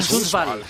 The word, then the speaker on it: Sundsvall: En el Sundsvall, que está en la zona baja de la tabla, además. – Qué bien lo pronuncia. – Muy bien, Sundsvall.